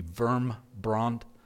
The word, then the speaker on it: Wurmbrand: I think, for instance, of the film that came out a couple of months ago on Richard Wurmbrand. I'm not pronouncing that properly. It would be Wurmbrand.